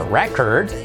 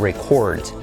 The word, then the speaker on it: record: La palabra grabar o grabación, tenemos el nombre que es a record, veis que se acentúa la primera sílaba, a record, y la, lo que sería el verbo, que sería to record, so record, record.